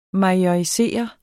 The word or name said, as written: majorisere